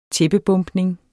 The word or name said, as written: tæppebombning